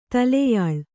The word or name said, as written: ತಲೆಯಾಳು